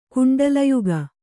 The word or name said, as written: ಕುಂಡಲಯುಗ